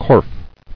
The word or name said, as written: Corf